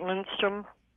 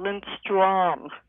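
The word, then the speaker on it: Lindström: Well, a lot of people that are not Swedish, they'll pronounce it Lindstrom, just plain. And put little dots above it, it's called Lindstrom. It's very strong in the pronunciation.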